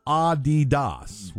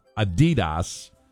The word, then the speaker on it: Adidas: It is supposed to be Adidas, with the emphasis on the first syllable, not Adidas, as we say, with the emphasis on the second syllable.